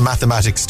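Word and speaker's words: +–=÷×: It's pronounced the mathematics tour.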